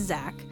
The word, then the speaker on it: Zach: My name is Zach. Spell it with a C-H.